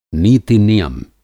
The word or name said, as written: नीति-नियम